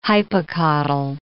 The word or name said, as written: hypocotyl